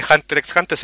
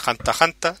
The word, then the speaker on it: Hunter x Hunter: No se dice Hunter X Hunter, se dice Hanta Hanta, no sé, la X no se pronuncia.